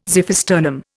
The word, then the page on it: xiphisternum: Indian